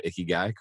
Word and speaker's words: Ikigai: I've seen these Venn diagrams before, and I'm pronouncing that correctly now. Ikigai, correct? – You are.